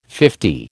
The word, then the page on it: fifty: American English